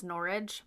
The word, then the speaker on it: Norwich: It's pronounced Norwich, but...